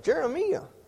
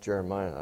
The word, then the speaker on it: Jeremiah: I said, Jeremiah. Well, that's what you call that southern draw from Arkansas there, I guess. Actually, it's pronounced Jeremiah, I believe.